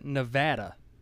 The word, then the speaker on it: Nevada: And you're out in Nevada. Did I say it right? – That's right.